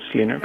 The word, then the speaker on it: Linux: I pronounce Linux.